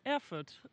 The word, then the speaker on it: Erfurt: I staden Erfurt. – Mm, bra uttal. – Tack. Eller det var alltså inte bra uttal? – Nej, Erfurt.